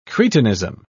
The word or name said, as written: cretinism